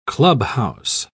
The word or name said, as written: clubhouse